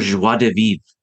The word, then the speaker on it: joie de vivre: Um, oh, and, how do you say uh, it? – Joie de vivre Joie de vivre. Okay, I'm going to use that.